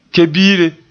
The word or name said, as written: ke-biire